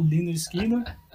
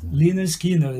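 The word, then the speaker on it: Lynyrd Skynyrd: Então ficou Leonard Skinner. E o nome chama Pronounced Leonard Skinner, explicando como é que se pronuncia é, bom, né?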